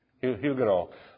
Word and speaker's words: Huguenot: They were how do you pronounce Huguenot? Huguenot.